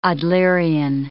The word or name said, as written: Adlerian